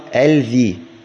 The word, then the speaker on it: الذي: التي تلفظ ولا تكتب مثلا الذي أنت تكتبها الذي، تكتبها الذي وتلفظها الذي، فتلفظها بلامين، تلفظها بلامين لكن هي تكتب بلام واحدة.